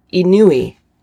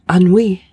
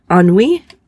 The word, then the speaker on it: ennui: But I think it's, you pronounce it Inui. I know I'm wrong, but I see how it's spelled E-N-N-U-I. And I'm like, oh, this word, what is this word? And so I'm fixated on this word and I finally Google it. Thank goodness for Google. And it is pronounced Anui.